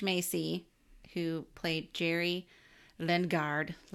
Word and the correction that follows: Lundegaard: Lundegaard. Yes. Jerry Lundegaard.